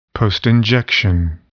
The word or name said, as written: postinjection